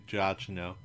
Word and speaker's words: Giacchino: Who I think we always pronounce his name Giacchino, which is fun, but I think it's actually Giacchino.